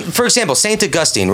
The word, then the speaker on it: Augustine: Augustine is a fine way to pronounce it.